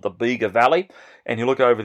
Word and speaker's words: Bega: It's called Bega, and it's spelled B-E-G-A, Bega.